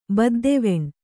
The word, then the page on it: ಬದ್ದೆವೆಣ್: Kannada